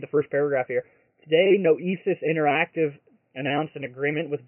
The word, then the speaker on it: Noesis: Neosis, but I was told that's not how you pronounce it. Nick said it sounds like thesis, only without the TH, so ESIS. Is that how you pronounce it, Nick? – Noesis.